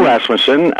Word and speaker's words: Rasmussen: Was the idea brought to you by Bill Rossman? Am I pronouncing that correctly? – Yeah, Bill Rasmussen was a fellow, and his brother, his son Scott.